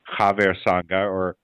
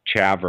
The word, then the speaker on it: Haver Sangha: Haver Sangha, or people might say, or people who don't speak Hebrew might say Chaver or something like that. But Haver Sangha.